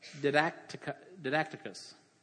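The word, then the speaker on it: didaktikos: The word apt to teach comes from the Greek word didacticus, that's the right way to say it, which is where we get the English word didactic.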